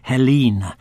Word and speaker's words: Helyne: Helene is spelt H-E-L-Y-N-E, but we'll avoid a detailed discussion on pronunciation, as it is Helene, recorded in L.A., August 61.